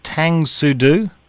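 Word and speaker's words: Tang Soo Do: Now, I'm not sure if I'm pronouncing this right. Is it Tang Sudu? – Yeah, that's correct.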